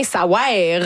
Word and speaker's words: Ça ouère: Ça veut rien, ça ouère. Vraiment, ça s'écrit de même, il faut le prononcer comme ça.